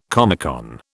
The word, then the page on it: Comecon: British English